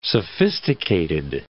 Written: sophisticated

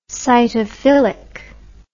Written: cytophilic

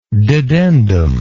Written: dedendum

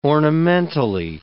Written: ornamentally

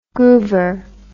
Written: groover

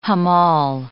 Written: hamal

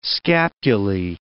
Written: scapulae

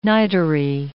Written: niterie

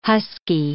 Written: husky

